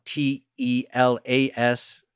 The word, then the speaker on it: Telas: Nico Jaar has a new one called Telas, and that's spelled T E L A S, Telas.